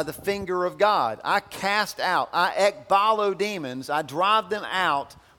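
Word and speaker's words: ekballo: But there's another word that he uses here, and the word is ekbalo. If you're taking notes, you want to spell it. It's E-K-B-A-L-L-O. Ekbalo. It's a fun word to say.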